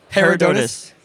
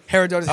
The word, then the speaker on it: Herodotus: Uh, when, how did you guys come up with the with the need for Her- Her- how you how, how you pronounce it? – Her- Herodotus. – Herodotus.